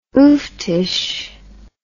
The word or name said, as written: ooftish